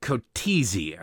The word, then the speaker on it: Coetzee: Her name is Angelique Kotzé, I believe is how you pronounce it, Coetzee.